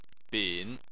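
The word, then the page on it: bin: tone 3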